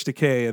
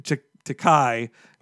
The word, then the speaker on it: Takei: My theory is that they thought they could get George Takei, and then they did Takei. – That's right. Wait, no, it's okay. – Damn it. I said it right the first time, and I doubted myself.